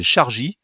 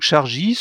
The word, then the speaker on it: Shargy: Et là, on a par exemple le groupe Volkswagen qui qui vient de lancer une application qui s'appelle Chargy. ça s'écrit S H-A-R-G-Y. Je sais pas exactement comment ça se prononce, mais c'est pas un C au début, c'est un S.